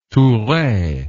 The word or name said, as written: toure